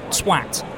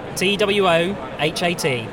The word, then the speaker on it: Twohat: T-W-O-H-A-T. It's at... – Two-hat. – It's two-hat, but they've made it one word, so it reads...